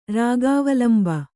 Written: ರಾಗಾವಲಂಬ

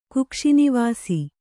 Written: ಕುಕ್ಷಿನಿವಾಸಿ

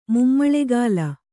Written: ಮುಮ್ಮಳೆಗಾಲ

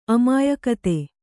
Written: ಅಮಾಯಕತೆ

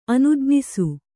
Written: ಅನುಜ್ಞಿಸು

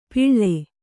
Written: ಪಿಳ್ಳೆ

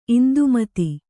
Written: ಇಂದುಮತಿ

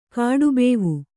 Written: ಕಾಡುಬೇವು

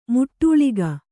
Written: ಮೆಟ್ಟಲಿಗೆ

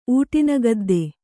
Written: ಊಟಿನಗದ್ದೆ

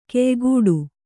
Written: ಕೆಯ್ಗುಡು

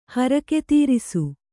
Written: ಹರಕೆತೀರಿಸು

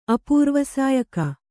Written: ಅಪೂರ್ವಸಾಯಕ

